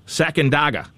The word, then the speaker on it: Sacandaga: And there, a recent discovery beneath great, let me say this right, Sacandaga.